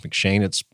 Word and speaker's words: M'Cheyne: Another plan that I've used in the past is called the McShane plan. I think it's pronounced McShane. It's spelled M'Cheyne.